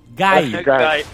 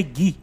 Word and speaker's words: Guy: Não, o cara é Gui. G-U-Y. Gai. Só que a pronúncia é Gui.